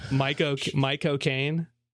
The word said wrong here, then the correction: Michael Caine: Michael Caine. – Yeah. – I let you down. Oh, no. – You know how to pronounce his name by saying my cocaine.